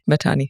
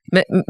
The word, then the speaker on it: Mahtani: Mahtani or Mahtani? – Mahtani, actually. – Mahtani. – Mahtani is the more Indian Sindhi pronunciation. – The way I say it. – That's correct. – That's correct. Mind. But the way I say it's probably wrong, frankly, which is Mahtani.